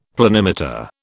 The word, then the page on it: planimeter: British English